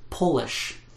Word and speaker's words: Polish: And sometimes the pronunciation actually changes too, like Polish of Poland, or polish, to make shiny.